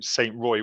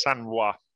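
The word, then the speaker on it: Saint Roi: I'm a British man in France, so I'd call him Saint Roy, wouldn't I? But yeah, I think it is Sanrois. So that's what we'll go with, and I think we got that right.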